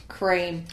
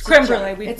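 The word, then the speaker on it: crème brûlée: It's creme brulee. – We know it's creme brulee.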